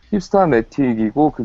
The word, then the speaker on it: Hipstamatic: 힙스타매틱이고, 그게 이제 스펠링이 HIPSTAMATIC.